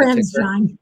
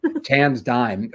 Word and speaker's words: TransDigm: He also mentioned, I have actually no idea how the stock is pronounced, but TransDigm or TransDigm, TDG is the TransDigm. – TransDigm. Oh, right.